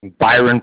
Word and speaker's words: Byron: Byron Parkin. – May you spell that for me, please? – B-Y-R-O-N.